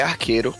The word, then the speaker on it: Archero: O nome dele é Arqueiro, se escreve A-R-C-H-E-R-O.